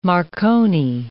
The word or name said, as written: marconi